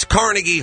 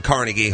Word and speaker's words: Carnegie: If you notice, they start messing with the pronunciation of words. Like, it's Carnegie Hall. It's always been Carnegie Hall.